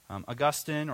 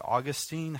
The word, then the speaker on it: Augustine: Augustine, or you could say Augustine, however you want to pronounce his name.